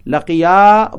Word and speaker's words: لَقِيَا: So this is a past tense verb, but we have to pronounce it with alif because it's a dual. If you say لَقِيَا, it means only one person. Laqiya, both of them, they met.